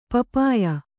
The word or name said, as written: ಪಪ್ಪಾಯ